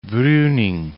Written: Bruning

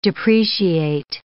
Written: depreciate

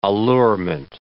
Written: allurement

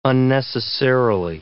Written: unnecessarily

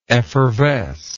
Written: effervescency

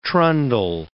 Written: trundle

